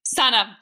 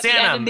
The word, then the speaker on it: Sanam: Did I say it right again? – Sanam. You got Sanam. The end and the M. It's fine.